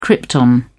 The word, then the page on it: krypton: British English